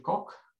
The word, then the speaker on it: Coq: So it's a French word for rooster, and I pronounce it coq.